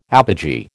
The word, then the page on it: apogee: American English